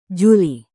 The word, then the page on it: juli: Indonesian